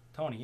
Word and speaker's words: Toney: Toney, yeah.